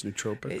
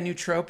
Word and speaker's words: nootropic: You take it at night, and the other one is just uh, it like I don't know if you'd call it a nootropic nootropic. How do you say it? I think it's nootropic. – Uh, yeah, nootropic.